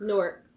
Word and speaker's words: Newark: Newark or Newark? – Newark, one syllable.